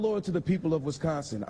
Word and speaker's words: Wisconsin: Wisconsin. – Yes, Wisconsin.